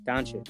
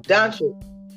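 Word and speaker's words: Dončić: Doncic. – His last name. – Doncic. – Doncic.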